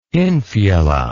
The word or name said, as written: infula